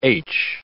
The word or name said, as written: aitch